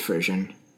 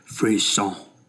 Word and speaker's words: Frisson: And then we went with Frisian, which is not pronounced Frisian. – It's pronounced Frisson. – Frisson. It's a French word, Frisson.